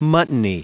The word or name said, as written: muttony